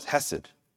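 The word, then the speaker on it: Chesed (חסד): Chesed, to say it correctly.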